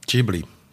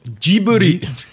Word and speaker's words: Ghibli: Ich kann nur kurz fragen, sagt man Ghibli oder Ghibli? – Ghibli. – Studio was? – Ghibli. – Ghibli. Ghibli, also ich habe es mein ganzes Leben falsch gesagt.